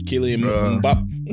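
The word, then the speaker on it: Kylian Mbappé: Kylian Mbappe.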